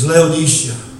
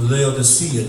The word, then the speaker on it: Laodicea: And the last church he writes to It's Laodicea or Laodicea, depending on how you want to pronounce it.